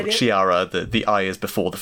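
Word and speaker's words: Cheetara: And then Chitara from Thundercats, I'm gonna try to pronounce her name, Chiara? – Chiara, yeah. – Chiara? Okay, I didn't have to. – I thought it was Charia.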